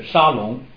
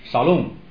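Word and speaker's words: 沙 龙 (שלום): 平安这个词希伯来语的发音就是沙龙，沙龙，沙龙，好像有个以前有一个前任的以色列总理叫沙龙，对吧？啊，耶和华沙龙，沙龙这个词就是平安的意思。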